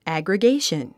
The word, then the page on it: aggregation: æ`grigéiʃən; アグラゲィション